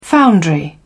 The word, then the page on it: foundry: British English